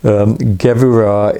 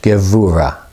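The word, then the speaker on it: Gevura (גבורה): yeah, vey is V-E-Y, vey. Gevura is G-E-V, V-O-O-R-A-H. It's the tree of life, that's the G-E-V, Gev. You are. Or double O-R, I'm trying to do it phonetically, G-E-V-O-O-R-A-H, Gevura. Gevura. – The V-A-Y, isn't it? – Yes, it is. It is. V-A-Y, Ve Gevura.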